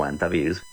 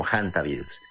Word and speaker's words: hantavirus: Ahí está en efecto ese virus que mencionas, de estos hantavirus o antivirus, se escribe con H y se puede pronunciar en español como antivirus, pero es muy conocido internacionalmente como hantavirus.